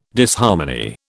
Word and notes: Disharmony: British English

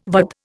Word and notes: VAD: Indian

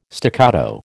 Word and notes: staccato: American English

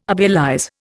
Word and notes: obelize: Indian

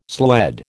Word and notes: sled: American English

